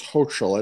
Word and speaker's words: Hochul: And after Andrew Cuomo resigned and the lieutenant governor, Kathy, I guess it's Hochul. I never quite know how to pronounce her name. I've heard it all different ways.